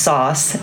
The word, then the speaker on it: SOS: SOS, which you know is phonetically pronounced sauce, and then the brand name sauce, which is a topping that you use to spice something up.